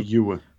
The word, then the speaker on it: Famuyiwa: Rick Famuyiwa, I might be saying that wrong. – Famuyiwa.